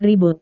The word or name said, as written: ribut